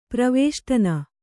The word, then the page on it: ಪರಿವೇಷ್ಟನ: pari vēṣṭana